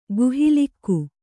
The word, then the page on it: ಗುಹಾಲೇಖ: guhā lēkha